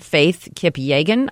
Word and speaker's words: Faith Kipyegon: So a professional woman runner from Kenya, Faith Kipyegon, I believe is how you pronounce her name.